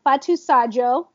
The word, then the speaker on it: Fatou Sadio: Um, Fatou Sadio. People don't know how to pronounce that, but it is at F A T O U S A D I O.